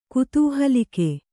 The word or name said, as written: ಕುತೂಹಲಿಕೆ